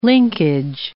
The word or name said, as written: linkage